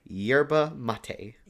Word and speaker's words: yerba mate: Yerba mate.